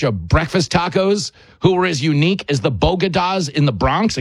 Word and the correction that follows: bodega: except there's only one small problem. It's pronounced bodega.